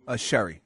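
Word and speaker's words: Chéri: Cherie is the way it's pronounced? – Sherry, sorry.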